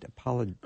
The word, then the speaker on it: apologist: And as an apologetist, did I say that right? – Apologist. – Apologist. Okay. Thank you for correcting me.